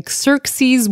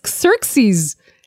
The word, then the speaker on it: Xerxes: First of all, scholars, please agree with me that it's Xerxes and not Xerxes. – Very obvious. I'm sure that between us, we've hit the right pronunciation at least once. I'm not saying when and I'm not saying who, but for me, I say Xerxes. You say, what is it? How do you say it? – Xerxes. – So I think Xerxes. I think the X when it's the first letter is like a Z.